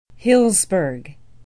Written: huilsburg

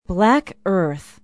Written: black earth

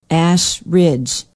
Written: ash ridge

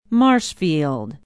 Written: marshfield

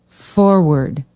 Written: Forward

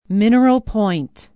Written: Mineral Point

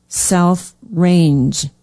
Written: south range

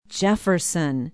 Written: Jefferson

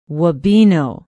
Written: wabeno

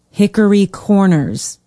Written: hickory corners

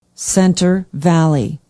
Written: center valley

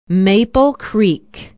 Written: maple creek

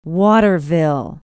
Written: waterville

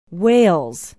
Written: wales

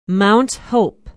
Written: mount hope

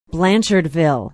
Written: blanchardville

